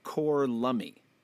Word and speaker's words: Corlummy: and I believe we should be pronouncing this Corlummy. Now, Corlummy. Okay. – It's basically Cockney slang for God love me.